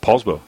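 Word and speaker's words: Poulsbo: So you grew up in, is it pronounced Paulsbo? – Paulsbo.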